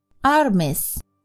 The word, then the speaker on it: armis: Armis 这个词的发音和英语中的 armies 相似，但并不表示军队，而是相当于英语中的 arms 武器。